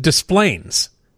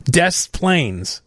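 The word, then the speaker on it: Des Plaines: And also, you know, they get Versailles and all these, you know, like, well, that's like Desplaines. It's pronounced, we pronounce it Desplaines. Desplaines. All right, here's Ken on WGN.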